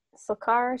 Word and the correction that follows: Stilgar: Stilgar, yeah.